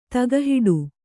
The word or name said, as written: ತಗಹಿಡು